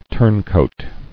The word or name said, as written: Turncoat